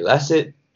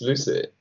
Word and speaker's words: Lxcid: Why Lucid spelled L-X-C-I-D? Like, should it be Lacid? Lacid? – Lucid? Lucid? – But unfortunately, it's pronounced Lucid. And the reason behind that, it's basically a collaboration between love and acid.